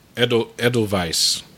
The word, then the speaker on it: Edelweiss: Edelweiss. That's the German pronunciation.